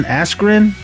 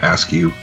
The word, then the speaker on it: Askren: Jake Paul, Ben Askren, is that how you say his name? – Yep, even right. Though the announcer announced his name, Askew.